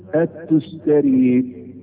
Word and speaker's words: التستري: فاعدل اللي يسموه تفسير القران العظيم للتستري، هكذا تنطق الكلمه التستري. التستري نسبه الى مدينه اسمها تستر، ودي من بلاد فارس وعفين، من حته اسمها خوزستان، من بلاد فارس يعني.